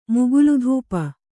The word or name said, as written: ಮುಗುಲುಧೂಪ